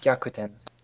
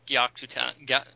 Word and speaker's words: Gyakuten: It's Getyukan Saiban. – Gyakuten. – Yeah, thank you. Gyakuten.